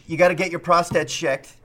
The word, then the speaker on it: prostate: It's pronounced prostate.